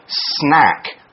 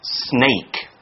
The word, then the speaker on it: snack: But be careful to pronounce it snack, not snake.